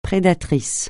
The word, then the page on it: prédateur: francais